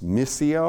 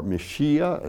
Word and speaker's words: Mysia: It's Mysia or Mysia, as some pronounce it.